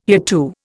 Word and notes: hereto: Indian

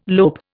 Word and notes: lope: Indian

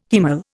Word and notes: haemal: Indian

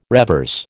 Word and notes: revers: American English

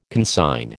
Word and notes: consign: American English

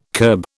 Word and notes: curb: British English

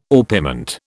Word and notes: orpiment: British English

